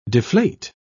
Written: deflate

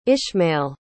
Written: ishmael

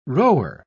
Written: rower

